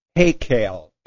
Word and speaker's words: Heykal: As usual, in reprint 1981, Brother Russell writes, the usual Hebrew term applied to the Jewish temple was, hopefully I'm pronouncing this correctly, Hekel. H-E-Y-K-A-L. How am I doing, brother? Okay.